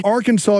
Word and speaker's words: Arkansas: Arkansas is the way you have to pronounce it.